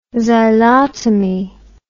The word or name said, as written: xylotomy